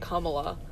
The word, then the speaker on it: Kamala: And they were talking about, like, the correct pronunciation. It's actually, like, Kamala.